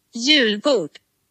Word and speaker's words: Julbord: Julbord also Jülboot, wenn das so richtig ausgesprochen ist.